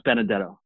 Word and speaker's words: Benedetto: And my last name is pronounced Benedetto.